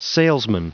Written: salesman

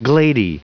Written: glady

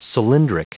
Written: cylindric

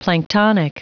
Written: planktonic

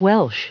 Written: welsh

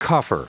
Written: coffer

